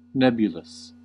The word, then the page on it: nebulous: American English